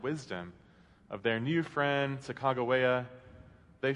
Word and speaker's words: Sacagawea: In the book, he uses as a central metaphor the expedition taken by Lewis and Clark and their companion, sometimes called Sacagawea, although linguists now think that perhaps a more accurate pronunciation may be Sacagawea.